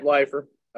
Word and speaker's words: Leifer: You said it right, Leifer.